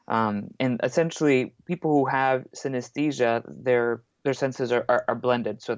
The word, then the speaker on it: synesthesia: Kingdom, there's actually an A in the middle of the word, so you mispronounce it like synesthesia. – Yeah. – But it's synesthesia, like anesthesia, but synesthesia.